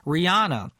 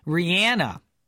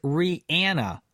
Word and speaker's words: Rihanna: Rihanna, she says Rihanna. Did you know that? The correct name way to say her name is Rihanna.